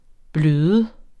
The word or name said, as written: bløde